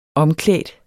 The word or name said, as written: omklædt